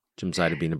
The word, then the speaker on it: gemcitabine: And I always say this incorrectly, but it's gemcitabine and Abraxane. – Yep. – Yes. Yes. – Yep. Gemcitabine, Abraxane.